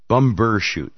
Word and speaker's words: Bumbershoot: Bumbershoot. – Bumbershoot. – I will not let you mispronounce Bumbershoot. – Bumbershoot. Bumper. – No, there's a B, not a P.